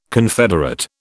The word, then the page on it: confederate: British English